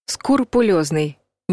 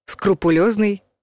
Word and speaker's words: скрупулезный: Скрупулезный — неправильный вариант. Скрупулезный — правильный вариант.